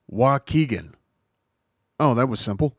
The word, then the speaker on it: Waukegan: And then you pronounce it and you're like, well, it's W-A-U-K-E. Yes, because key's a letter. W-A-U-K-E-G-A-N. And I'm like, what? Because there's very few words that I know offhand that have a U as the third letter. And so I was like, how do you pronounce? Oh, Waukegan. Okay, that makes sense.